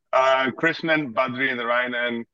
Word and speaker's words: Krishnan Badrinarayanan: Krishnan Badrinarayanan.